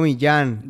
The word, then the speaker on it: Millán: Diego Millán, M I W L acento en la A N.